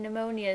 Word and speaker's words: pneumonia: I've never heard The P in pneumonia is silent.